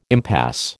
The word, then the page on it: impasse: American English